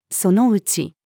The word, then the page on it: その内: female